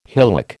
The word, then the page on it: hillock: American English